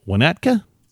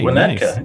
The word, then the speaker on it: Winnetka: Let's see if I can say this right. Frank in Winnetka? – Yeah, correct. All right, Winnetka. Nice.